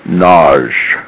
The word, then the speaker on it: Nagy: First thing I'll do is I'll tell you that I pronounce my last name in a contrarian manner, the way it's sort of pronounced in Hungary, which is as one syllable, Nagy. – Ah, totally apologize. – Nagy. Nagy.